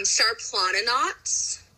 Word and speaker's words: Šarplaninac: um, Sarplaninacs.